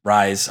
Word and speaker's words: Rhys: I'm not sure if he pronounced his middle name. I think it's Rhys, R-H-Y-S.